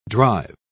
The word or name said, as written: drive